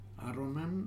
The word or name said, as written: Aronan